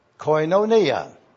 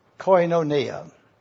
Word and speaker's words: koinonia: On the screen, you see now again the word koinonia.